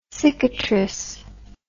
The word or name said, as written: cicatrice